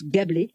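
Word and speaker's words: GaBLE: Gablé se prononce Gablé, mais s'écrit G majuscule A minuscule B majuscule L majuscule E.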